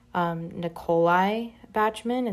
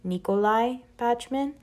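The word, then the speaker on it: Nicolai Bachman: It's not Nikolai Bachman. It's not a woman. It's a man. It's Nikolai Bachman.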